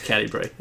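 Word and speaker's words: Catti-brie: Cat Bray. Cat. – Roll another deception check. – Oh, God, he can't even get the name right. Fuck. – I'm pretty sure it's Catty Bray, isn't it? – Is that called her mom?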